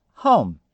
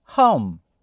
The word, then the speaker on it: home: A pronúncia da palavra é home. Home. Ela é uma palavra monossílaba. Não se engane, você vai ver HO, vai supor que seja uma sílaba e o ME é outra. Não. A pronúncia é home. Ela só tem uma sílaba.